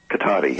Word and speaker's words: Cotati: Cotati, yeah.